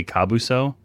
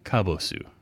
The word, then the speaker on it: Kabosu: So, I always want to say Kabuso. The dog's name is Kabosu.